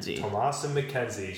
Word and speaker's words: Thomasin McKenzie: It's a movie with, the most famous actress in it would be, how do you pronounce her? – Thomasin McKenzie.